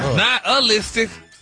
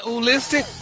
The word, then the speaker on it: nihilistic: But you actually pronounced it correctly the last time you said it. – Really? Not oolistic? – Wow. – Stop putting the ooh in there.